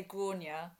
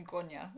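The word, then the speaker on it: Gráinne: I mean, it's one of those ones where you pronounce it differently in Ireland compared to England. I say Grainne, but Grainne. I always find it in an English accent, like the Grainne. My mum calls me Grainne.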